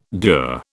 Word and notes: dour: British English

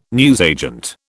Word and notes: newsagent: British English

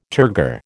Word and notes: turgor: American English

